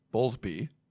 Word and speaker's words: Bowlesby: Um, as far as I think it was the Athletic that broke the story that Bob Bowlesley, Bowlesby, sorry, I keep saying his name wrong, Bowlesby.